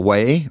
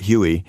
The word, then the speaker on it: Hue: Yeah, and they'll say, like, they call Hue, which which is spelled H-U-E. They still call it Huey.